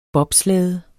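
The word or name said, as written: bobslæde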